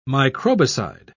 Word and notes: microbicide: British English

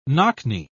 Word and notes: knock-knee: British English

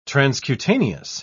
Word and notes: transcutaneous: British English

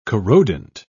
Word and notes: corrodent: British English

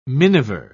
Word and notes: miniver: British English

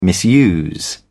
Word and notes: misuse: British English

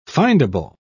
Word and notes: findable: British English

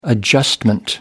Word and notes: adjustment: American English

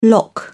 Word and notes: lock: British English

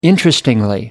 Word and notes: interestingly: American English

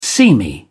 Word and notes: seamy: British English

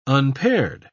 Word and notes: unpaired: British English

